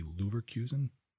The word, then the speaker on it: Leverkusen: Hope I'm saying this correctly. Leverkusen? – Sounds good to me.